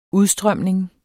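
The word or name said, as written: udstrømning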